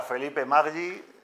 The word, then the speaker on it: Felipe Maggi: Bueno, pues hoy vamos a conocer a Felipe Maggi. ¿Lo he dicho bien? Sí.